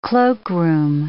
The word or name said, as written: cloakroom